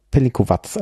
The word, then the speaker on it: Pelinkovac: Also Pelinkovac. Also das ist jetzt ein Beispiel dafür, dass das C hinten wie ein Z ausgesprochen wird.